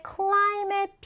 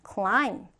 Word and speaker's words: climb: 자, 이때 climb의 b는 우리가 발음하지 않아요. 그쵸? b는 발음하지 않고 climb, climb 이렇게 발음을 합니다.